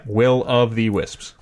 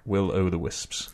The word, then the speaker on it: Will of the Wisps: And the Will of the Wisps. – Yeah, which is exactly how it's spelled. – Yeah. Will of the Wisps. – Yeah. – Not Will-O-The-Wisps. – Correct.